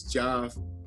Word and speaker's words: Geoff: I mispronounced the name of this incredible bass player, which I promise to never do again. His name is Joff.